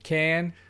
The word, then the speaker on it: Cannes: Cannes.